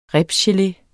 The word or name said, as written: RIBSGELE